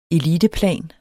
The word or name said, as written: eliteplan